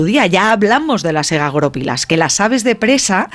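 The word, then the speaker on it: egagrópilas: Egagoropilas. – Vamos a poner el acento en la O.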